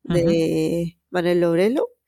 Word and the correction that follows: Loureiro: si no me equivoco la pronunciación, creo que la he dicho mal. Loureiro, creo que sí, sí, sí.